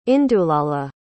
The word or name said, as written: indulala